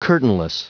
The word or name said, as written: curtainless